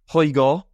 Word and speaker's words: Højgaard: So apart from that, let's just quickly look back at the Maiden Himmerland end, because a home winner, Rasmus Højgaard, as Thomas Bjorn told me to pronounce it.